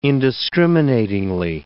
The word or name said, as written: indiscriminatingly